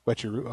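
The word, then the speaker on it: Uechi-ryu: Wechi Ryu? Is that how you pronounce Wechiru. It? – Yeah. – Wechi Ryu? Okay.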